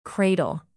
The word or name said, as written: cradle